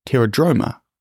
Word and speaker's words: Pterodroma: Pterodroma begins with a silent P, just like in the word pterodactyl.